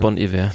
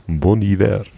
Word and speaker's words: Bon Iver: War bon Iver. Bon, ja. bon Iver.